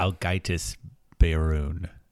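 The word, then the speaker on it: Ágætis byrjun: but Agitas Burjun on shrooms was incredible. I recommend that. – Algaitis Beirun. Algaitis. – Yeah, that makes sense. The J should probably be pronounced like a Y.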